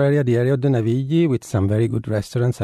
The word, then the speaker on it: Navigli: Navigli.